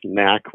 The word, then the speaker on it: NAC: Where do you land on the NAC versus NOC conundrum? – Well, the board of directors over 30 years ago preferred that the acronym be pronounced NAC rather than NOC. Yes, they wanted to avoid knock-knock jokes, but...